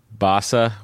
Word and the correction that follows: Barossa: What is it? – Barossa. The Barossa Valley. – Of course, where all the wine comes from. – Yep. – It's silly of me not to say that correctly.